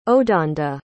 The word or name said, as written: odanda